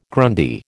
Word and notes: Grundy: American English

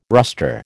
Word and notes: rustre: American English

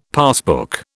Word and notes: passbook: British English

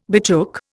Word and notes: betook: Indian

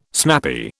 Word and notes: snappy: British English

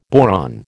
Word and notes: boron: American English

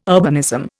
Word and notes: urbanism: Indian

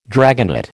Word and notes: dragonet: American English

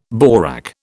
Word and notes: borak: British English

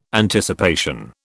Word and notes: Anticipation: British English

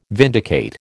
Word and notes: vindicate: American English

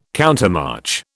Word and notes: countermarch: British English

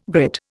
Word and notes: grit: Indian

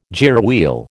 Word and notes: gearwheel: American English

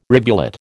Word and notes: rivulet: American English

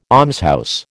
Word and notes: almshouse: American English

